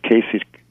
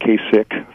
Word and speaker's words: Kasich: I'm glad to see somebody knows how to pronounce Kasich, not Kasich. Thank Jimmy, you're correct 100%.